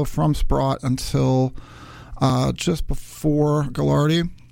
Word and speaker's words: Gagliardi: Gagliardi, sorry, that's how they pronounce it.